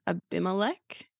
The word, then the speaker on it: Abimelech: I think straight up when starting this chapter, I want to know a bit of background context on Abimelech.